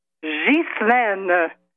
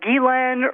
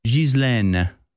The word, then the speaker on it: Ghislaine: It's Ghislaine. It's not Ghislaine or Ghislaine. There isn't one person in media or at the Justice Department, even pronouncing her name correctly.